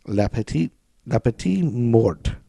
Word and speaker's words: la petite mort: There's a French phrase, la petite mort. La petite mort, is that how you say it? – I'm pretty sure.